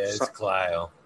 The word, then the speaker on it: Clio: Is it Clio? Is that how you pronounce it? Clio, Michigan? – Or yeah, it's Clio.